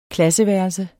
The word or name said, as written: klasseværelse